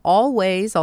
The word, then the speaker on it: Alvvays: A band out of Toronto called Always, although they spell it A-L-V-V-A-Y-S, and that always makes my eyes go cross. But they call themselves Always, and that song called Next of Kin, the album produced by Chad Van Galen.